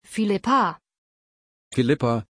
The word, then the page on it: philippa: German